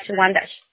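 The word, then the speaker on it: Wyandanch: W-Y-A-N-D-A-C-H. Wandash.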